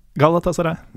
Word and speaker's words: Galatasaray: Galatasaray.